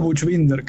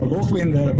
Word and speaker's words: Buchbinder: Yo digo Buchbinder, que es como la pronunciación medio castellanizada, sí. Pero Buchbinder, Buch, esa es la pronunciación alemana, está increíble.